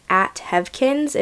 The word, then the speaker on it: Heavkinz: my Instagram handle is @hevkins. It's spelled H-E-A-V-K-I-N-Z.